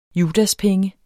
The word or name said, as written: judaspenge